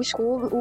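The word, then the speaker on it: Skoob: O Scoob, o B é mudo, hein, galera? – Ok, Scoob.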